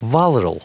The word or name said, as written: volatile